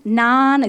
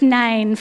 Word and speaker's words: Nain: I'm like, how do you say that? Nain? Naan? Is it naan bread? It's Nain for anyone who wants to know.